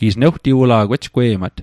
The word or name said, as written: ejgwe'mat